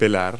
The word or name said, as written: peel